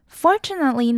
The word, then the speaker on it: Fortunately: 再来看到 Fortunately， Fortunately 呢我们要注意到的是 TU 的发音，像我们知道的 Tune T-U-N-E， TU 就是发 T 的音，但是呢在这里 TU 就会发 Ch 的音， Fortunately， Fortunately。